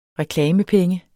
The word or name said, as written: reklamepenge